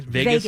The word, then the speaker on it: vagus: Is it called vagus? – Vegas. Las Vegas. – It's spelled V-A-G-U-S.